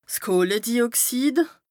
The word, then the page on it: Kohledioxid: Bas Rhin